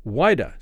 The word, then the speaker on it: Whydah: But nearly 35 years later, he was called back into service for England to captain a slave ship called the Whydah, named after the West African slave port Whydah, spelled O-U-I-D-A-H, but pronounced the same.